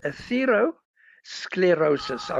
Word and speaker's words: atherosclerosis: Now, Doc, my uncle had an operation for a condition known as atherosclerosis. Now, what is this and how did he get it? – Right. I'm going to correct you a little bit there. It's called atherosclerosis. I'm going to spell it for our listeners.